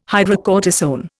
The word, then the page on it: hydrocortisone: Indian